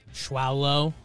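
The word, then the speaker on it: Schwolow: Alexander Schwalau. Schwolo. Schwolo. How would you pronounce that name? Schwolo? Schwolo? – Schwolo. I like that.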